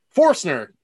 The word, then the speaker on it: Forstner: Did I say that right? – Forstner. – Ah, it's close. I switched the R and the O.